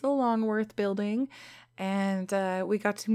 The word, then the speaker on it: Longworth: And the next day, I actually didn't get to go to a lot of the conferences because I was going to the Longworth building. And I think I said it right this time. I kept calling it the Longhorn building. And Sarah kept correcting me.